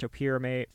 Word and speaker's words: topiramate: Alcohol and topiramate, most might know this as Topamax, I've also heard it pronounced topiramate, found in her system were thought to have hastened the effects of hypothermia sustained by Jenkins remaining inside the freezer.